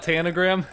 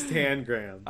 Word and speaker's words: Tangram: It's not Tanagram? – Yeah, I had to look it up while I was watching the episode because it was driving me crazy because everyone kept pronouncing it in different ways. I was like, I had to know which one was the right one, and it is Tangram.